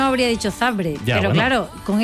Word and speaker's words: Zabrze: ¿Por qué sabe que es Zabrze? ¿Por qué pronuncias porque lo sé. – Zabrze? – Porque es así.